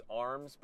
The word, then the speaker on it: Haarms: Maybe it's pronounced arms, but it's spelled HAARMS.